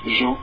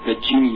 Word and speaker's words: Jean: Oh, it's a very French sound. No one has an O sound, I think, besides French. So I mean, just say it like you want. But Jean is the American pronunciation.